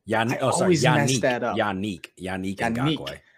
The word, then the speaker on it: Yannick Ngakoue: Leonard Floyd, Frank Clark, And I always, how do you say Yannick? – Yannick and Ngakoue. Yannick Ngakoue. Yeah.